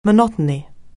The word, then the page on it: monotony: anglais